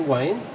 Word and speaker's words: Wain: My name's Peter Wayne, and the second part is spelled W-A-I-N.